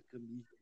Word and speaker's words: Camus: Eu nem sei direito como pronuncia, como é? Repete, por favor. – Pois é, é né? Albert Camus, se escreve Albert. Camus, né? Ah, ah tá. – fala Camus.